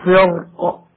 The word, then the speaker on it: فرق: وبعضهم يرى أن الترقيق أولى، وذلك لوقوع الراء الساكنة بين كسرتين، ولأن حرف الاستعلاء وهو القاف وقع مكسورا بعد الراء وكسره أضعفه، ولذلك قالوا إذا وقفنا على الكلمة وسكنت القاف من أجل الوقف ففي هذه الحالة التفخيم أولى، كما ننطق هكذا فكان كل فرق.